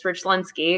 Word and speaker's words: Richlinski: I was like, well, my last name is Polish. It's Richlinski because my dad is from Chicago, but my entire family's from South Louisiana.